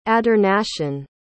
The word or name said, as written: adornation